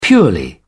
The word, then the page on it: purely: British English